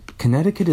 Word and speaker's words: Connecticut: Connecticut is a weird word to spell. Because there's like a silent C, which you don't normally see.